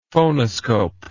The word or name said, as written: phonoscope